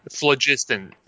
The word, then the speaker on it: phlogiston: Phlogiston. If you tell me it's phlogiston, I'm going to go with that. – Soft G in front of an I, phlogiston.